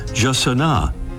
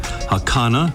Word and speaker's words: jacana: About those pronunciations, the Brazilian Portuguese way is Jacana, Spanish versions include Hakana and Hasana and Hasana.